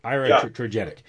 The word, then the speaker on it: iatrogenic: How do you pronounce it? Iatrogenic? Yeah, iatrogenic.